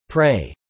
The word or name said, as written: prey